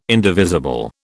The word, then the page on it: indivisible: British English